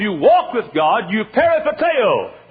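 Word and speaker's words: peripateo (περιπατέω): The word walk in the Bible is the word in the Greek peripateo. Uh, P-E-R-I, P-A-T-E-O is about the way we pronounce it.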